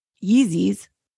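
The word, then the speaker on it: Yeezy: And his biggest moneymaker, the sponsors of his Yeezys, that's how you say it, right? – Yeah.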